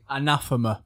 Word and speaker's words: Anathema: how do you pronounce their name? – Anathema. – Anathema. I was going to say Anathema.